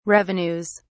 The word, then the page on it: revenues: British English